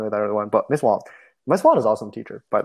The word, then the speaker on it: Wang: It's W-A-N-G, so you think it's like Wang, but it's Wong.